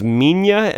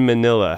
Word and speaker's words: Minilla: that pronounced as Minya and Minilla.